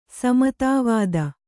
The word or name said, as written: ಸಮತಾವಾದ